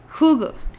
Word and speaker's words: Hygge: Y antes de esto tuvo otro librito así bestseller que se llamaba The Little Book of Hugo. Que no me oiga alguien que sepa hablar danés. Pero bueno, se escribe Hygge.